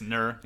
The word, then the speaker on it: Nur: I had Nuur as number. Is that how you say it? Nuur? Number two? – Just Nur, yeah.